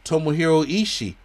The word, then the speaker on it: Tomohiro Ishii: Tomohiro Ishii was out.